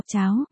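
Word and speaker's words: chào (朝): Nguyên văn chữ Triều là chào, chữ này có hai cách đọc cháo, và chào. Tên của nhân vật là chào có nghĩa là sáng sớm, bình minh, rạng đông.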